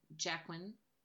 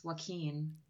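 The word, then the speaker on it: Jaquin: Elena rides the flying Jacquin, which I always want to pronounce Joaquin. – Yeah. – Because of the way it's spelled, like Joaquin Phoenix.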